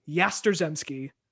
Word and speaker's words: Yastrzemski: No, nope, it's a silent Z. – Oh, let's go. – YASTRZEMSKI. Yasterzemski. – Yamsterzemski. – There's no M. There's no M to start the name. You're saying Yamsterzemski is Yasterzemski.